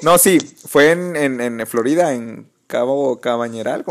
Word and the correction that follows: Cañaveral: ¿cómo se dice? Cabo Cañaveral. Cañaveral. ¿Cómo dije Cabañeral. ok, vale.